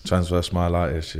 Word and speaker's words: transverse myelitis: So you were diagnosed with, I'm going to try and pronounce this, transverse myelitis. – Yes, transverse myelitis, yeah.